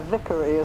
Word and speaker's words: Vicary: Before I married, my surname was Neale, spelt N-E-A-L-E, rather like my Vicary is spelt V-I-C-A-R-Y.